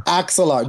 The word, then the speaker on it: Axelar: And again, this is, I will say it wrong, Alexar. Is that the variation? – Yeah, you know, I have no idea how to pronounce that. – Like an axle for a car? – Axelar. There you go.